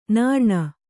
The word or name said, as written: ನೀರುಣ್